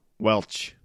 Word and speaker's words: Welsh: Just lives in a trailer out in the middle of nowhere, north of Welch, Louisiana. And spelled like Welsh. But, you know, everybody around there just calls it Welch. Like the grape juice.